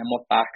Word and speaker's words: Motax: Mas é engraçado, cara, porque querendo ou não, essa pronúncia é uma pronúncia inglesa, né? Motax desse jeito.